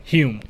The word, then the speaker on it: Hume: Hume. – Hume. I was going to say Hume. Or at first I thought it was Humes. And I was going to say, is it hummus? – Hume.